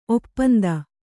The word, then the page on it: ಒಪ್ಪಂದ: Kannada; oppanda